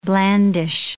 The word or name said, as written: blandish